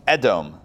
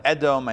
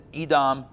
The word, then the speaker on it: Edom: to the fields of Edom. I'm going to call it Edom. I know in English maybe you pronounce it Edom, but in Hebrew it's Edom.